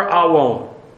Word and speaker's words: awon: Awon. A-W-O-N.